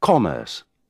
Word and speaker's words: commerce: Remember to put the stress on the first O in commerce.